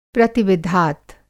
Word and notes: प्रतिविधात: Hindi

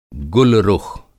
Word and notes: गुल रुख़: گُل رُخ

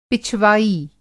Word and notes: पिछवाई: Hindi

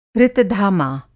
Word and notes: ऋतधामा: Hindi

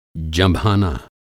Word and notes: जँभाना: Hindi

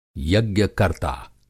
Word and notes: यज्ञ-कर्ता: Hindi

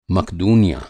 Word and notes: मकदूनियाँ: Hindi

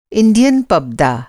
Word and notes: इंडियन पब्दा: Hindi

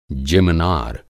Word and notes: जिमनार: Hindi

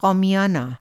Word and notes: क़ौमियाना: قومیانا

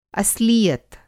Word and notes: अस्लीयत: Hindi

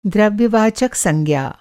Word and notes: द्रव्य-वाचक: Hindi

